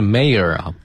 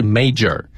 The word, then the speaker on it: mayor: 整个的节日将由市长宣布正式启动，正式开始。这个 mayor, M-A-Y-O-R, 就是市长的意思。 – 嗯，读音是 mayor, 不是 major。 – 对。 – 啊，这个单词看上去有点像 major, 就差一个字母。 – 哎。 – 但实际上它读的是 mayor, 它的拼写是 M-A-Y-O-R。